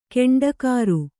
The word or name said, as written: ಗೂಂಡಾಗಿರಿ